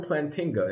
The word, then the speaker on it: Plantinga: Properly, in fact, I did get his name right. It's Alvin Plantinga, and the book is called Where the Conflict Really Lies, Science, Religion, and Naturalism.